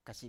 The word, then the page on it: kasip: Biak